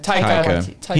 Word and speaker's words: Taika: So it's important to note it was written and directed by Jermaine Clement and Takiya Waititi. Takiya Waititi. – I butcher his name too, which is unfortunate because Taika.